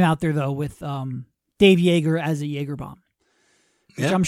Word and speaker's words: Joerger: And I guess if you're curious, Joerger, spelled what? J-O-E-R-G-E-R, pronounced Joerger, like Joerger, or like a Joergerbaum.